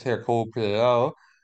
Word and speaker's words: Hercule Poirot: The latest Hercule Poirot uh, movie. Notice how I pronounced that correctly.